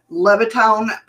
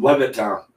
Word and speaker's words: Levittown: The only other report received by the society in the area was from Levittown, Pennsylvania, roughly 50 miles. Did I say that wrong? – Yes, you did. – Okay, what is it? – Levittown? Levittown. – Okay. – Levittown, PA.